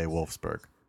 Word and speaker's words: Wolfsburg: I will say Wolfsburg.